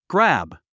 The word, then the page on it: grab: grǽb; グラブ